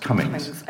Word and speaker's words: Comyns: It's spelt Comyns, but it's pronounced Comyns.